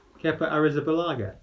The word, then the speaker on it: Kepa Arrizabalaga: How do you say his name? – Kepa? – You said it earlier. – Kepa Arrizabalaga. – Yeah. Fair play.